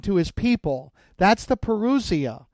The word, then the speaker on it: parousia: You most often will hear it pronounced parousia. Some people, keeping the Greek pronunciation, will say parousia.